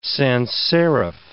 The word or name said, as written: sanserif